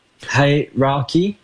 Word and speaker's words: hierarchy: Hierarchy? How do you say it? – Hierarchy. – That's so hard to say.